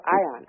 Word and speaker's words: Ion: How do you spell that second word? – Yon, I-O-N. – Oh, Ion, okay. – Ion, sorry. – Sorry, yeah. southern drawl that I have, sorry.